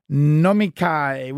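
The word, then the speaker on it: nomikai (飲み会): They do what's called nomikai, which is a drinks.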